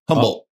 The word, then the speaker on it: Humble: H-U-M-B-L-E. – Pronounce that city. – Humble. – Nope. It is Humble. – Yep. Oh, Humble. – With a U. – Humble, Texas. – Yeah. – It's a quiet H.